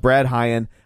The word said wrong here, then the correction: Braden Hyen: I know I'm saying that wrong. It's Braid Hyen, right? – Braden Hyen. – Yeah.